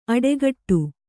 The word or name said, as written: ಅಡೆಗಟ್ಟು